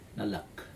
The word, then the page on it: nalak: Aneityum